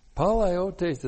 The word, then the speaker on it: palaiotes: Palaiotes is a hapax legomenon. Oh, no, not one of those. P-A-L-A-I-O-T, long E-S, palaiotes.